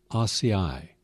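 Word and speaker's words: açaí: That's a real superfruit, and it's pronounced ACEI, as if it were A-H S-I-G-H-E-E, but it's spelled A-C-A-I.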